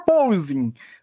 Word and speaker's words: porpoising: Chegou a hora então, né, da gente responder a pergunta por porpoising. O que é, como vive, do que, que se alimenta. – você não está enfocando a sílaba certa. A primeira sílaba, porpozinho. Põe o circunflexo né? mental. Porpoising.